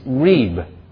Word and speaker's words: rib: It's spelled or transliterated R-I-B, like rib, but pronounced reeb.